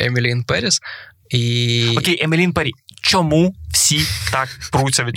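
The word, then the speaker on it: Emily in Paris: Повертається Емілін Періс, другий сезон. А ти знаєш, правильно читається Емілі Ін Парі.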